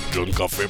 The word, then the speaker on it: John Kafé: John Café, patron. Comme le café, mais ça s'écrit pas pareil.